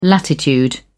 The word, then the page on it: latitude: British English